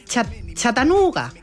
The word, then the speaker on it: Chattanooga: Nos vamos a Chattanooga.